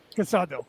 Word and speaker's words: Casado: Casado.